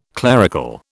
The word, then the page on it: clerical: British English